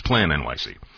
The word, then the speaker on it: PlaNYC: It's spelled PLANYC. It's not PLA NYC, it's Plan NYC.